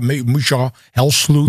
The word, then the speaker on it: Helsloot: It's always Misha Helsloot. – Misha Helsloot. – It becomes everything. And I've always been looking for how to write down my name so in English they understand. So they pronounce it as Dutch.